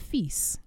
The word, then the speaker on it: fils: And I said fils, which is correct.